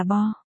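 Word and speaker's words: bo (白): Trong kinh kịch mọi người không phát âm chữ này là bai mà là bo.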